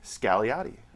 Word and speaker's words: Scagliotti: After how do you pronounce the name? – Scagliotti. – There's no G.